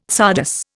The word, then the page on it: csardas: Indian